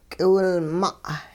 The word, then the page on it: k'iwilma': hupa